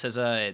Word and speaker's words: SZA: It's pronounced SZA.